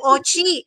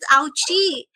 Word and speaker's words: Ochi: Now, the story behind it, why it became Ro, is because nung nag-start ako ng mag-work dito, hindi nila ma-pronounce yung Ochi. They pronounce it as Ouchi. And it Ouchi. Feels so weird to be called Ouch, Ouchi.